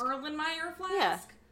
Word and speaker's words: Erlenmeyer flask: And then the finale, if you know how to pronounce this, you let me know, girl. I'm pretty sure it's... – Where? Erlenmeyer Flask? – Erlenmeyer Flask? – Yeah.